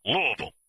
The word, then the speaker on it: Louisville: He said Loserville, but pronounce it the right way, please. Louisville.